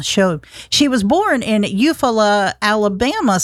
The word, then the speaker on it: Eufaula: Eufaula.